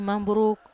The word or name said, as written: mamburuk